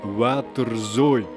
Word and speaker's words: waterzooi: Ok, pour tous les écouteurs flamands, waterzooi.